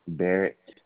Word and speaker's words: Barrett: Are you saying Barrett Lakes or Bear Lakes? – Barrett, B-A-R-R-E-T-T.